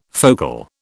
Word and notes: focal: British English

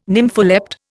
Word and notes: nympholept: Indian

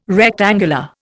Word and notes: rectangular: Indian

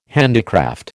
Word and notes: handicraft: American English

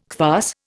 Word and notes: kvass: Indian

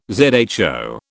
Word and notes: zho: British English

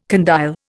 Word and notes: condyle: Indian

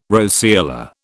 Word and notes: roseola: British English

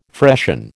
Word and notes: freshen: American English

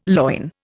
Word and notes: loin: Indian

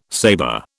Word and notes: saber: British English

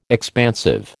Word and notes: expansive: American English

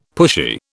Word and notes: pushy: British English

